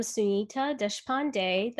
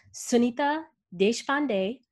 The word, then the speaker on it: Sunita Deshpande: Hi, I'm Sunita Deshpande. That's, if you want to pronounce it with the Indian accent, it's Sunita Deshpande, which you did really well.